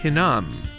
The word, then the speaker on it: Hinnom: 넵도아를 넵도아 이렇게 발음해 주시고, 흰놈의 아들 골짜기, 골짜기는 Valley고 흰놈은 히남 이렇게 발음합니다. 그래서 잘 듣고 따라해 보시면 The Valley of Ben Hinnom.